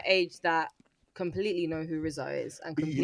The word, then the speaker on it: RZA: is it RZA? How do you say that? – RZA.